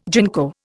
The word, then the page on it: ginkgo: Indian